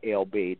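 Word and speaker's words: El Bey: which is Tahaka Amana LB. That's T-A-R-H-A-K-A. Amana is spelled A-M-A-A-N-A, and then E-L, and then B-E-Y.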